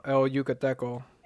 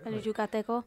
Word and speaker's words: El Yucateco: Uh, El Yucateco. How you say it? – You said it right. – Oh, yeah, cause I'm, I sound like I'm fucking white. – El Yucateco.